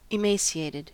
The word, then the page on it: emaciated: American English